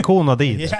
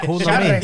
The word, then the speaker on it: Konami: Konami. Ikke kona di. – Konami. – Konami.